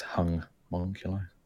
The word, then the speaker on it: Homunculi: It's pronounced Hung-munculi. – The Hung-munculi.